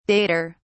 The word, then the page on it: dater: British English